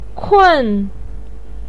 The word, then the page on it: kun: 4